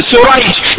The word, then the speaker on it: سريج: ابوهم زي بعض ده النعمان وده ايه لكن الاول الراوي اسمه ايه الاولاني بالشين شريح بالشين والحاء المهمله والاخر بالسين والجيم الايه المعمى جمع سريج.